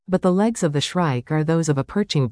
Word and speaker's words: shrike: Field guides will have it indexed under its more common name, Northern Shrike, rhymes with strike.